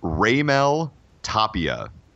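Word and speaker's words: Raimel Tapia: By the way, StatsPass, our proprietary Stats LLC database, has pronunciation for Tapia. It is Raymel Tapia.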